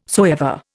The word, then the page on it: soever: Indian